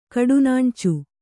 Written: ಕಡುನಾಣ್ಚು

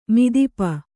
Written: ಮದಿಪು

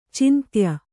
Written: ಚಂದಾಯ

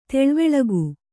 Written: ತಿಳಿವಳಿಕೆ

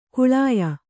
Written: ಕಾಳೇಯ

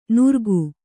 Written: ನುರ್ಗು

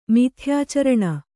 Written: ಮಿಥ್ಯಾಚರಣ

